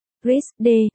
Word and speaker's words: RISD: The Rhode Island School of Design, RISD, phát âm là RISD, là một trường nghệ thuật và thiết kế tư nhân ở Providence, Rhode Island.